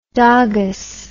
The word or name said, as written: dogess